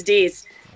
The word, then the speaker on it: Dease: Your name is Deese. – Wow. – So yeah. – That's how you say it.